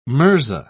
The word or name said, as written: mirza